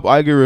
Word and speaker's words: Iger: Did I say that right, Iger? – Yep.